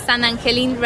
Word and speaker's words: San Angel Inn: It's like the G in English, you would pronounce it angel, and Spanish would be Angel. Angel, San Angelin.